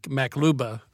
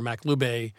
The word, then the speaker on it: maqlubeh: I fortunately, a couple years ago, traveled to the Middle East, and I spent some time with a Palestinian family, and they make maklouba or maklube. M-A-Q-L-U-B-E-H, but it's spelled 10 different ways.